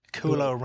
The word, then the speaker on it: Kualoa: Kualoa Ranch, which is apparently where they filmed Jurassic Park.